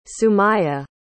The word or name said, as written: sumaya